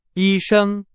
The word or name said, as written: 医生